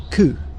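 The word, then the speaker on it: cu: The word cow comes from Old English, and I think Middle English was the same. Coo. – Hmm. – So it's the old pronunciation, and it's similar to how it's pronounced in parts of Scotland today.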